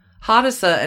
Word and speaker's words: Hadassah: Hadassah? I hope I'm saying that right. H-A-D-A-S-S-A-H. – So, yeah, Hadassah.